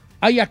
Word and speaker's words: haya: ¿Aya qué? – No se dice aiga.